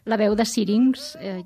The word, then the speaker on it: La Veu de Syrinx: No sé si ho he pronunciat bé, això, perquè ara, abans de començar l'entrevista fora de micròfons, et preguntava com voleu que es pronunciï, i suposo que hi ha una història al darrere d'aquest nom, no? – Sí, exactament. La Veu de Syrinx, ja ho has dit bé.